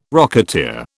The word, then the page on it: rocketeer: British English